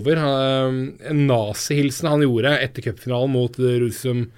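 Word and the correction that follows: Ružomberok: eh, Ruzjomberok. – Eh, ja, Ruzjomberok, eh, som det selvfølgelig uttales i fjor.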